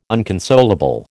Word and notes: unconsolable: American English

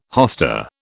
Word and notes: hosta: British English

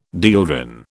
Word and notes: dieldrin: British English

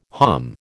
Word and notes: hom: American English

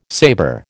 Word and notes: saber: American English

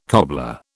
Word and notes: cobbler: British English

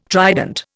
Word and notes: trident: Indian

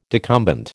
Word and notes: decumbent: American English

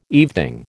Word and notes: evening: American English

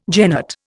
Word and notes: genet: Indian

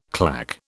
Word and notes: claque: British English

